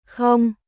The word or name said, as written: Không